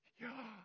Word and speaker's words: Yahweh: It is such a beautiful name because in Hebrew, you have to inhale and then exhale to say the name correctly. Yahweh.